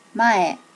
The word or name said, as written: mae